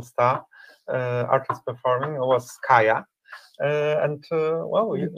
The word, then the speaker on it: Kayah: Uh, so I made friends with, how do you say her name? Kayah? Kayah, Kayah yes.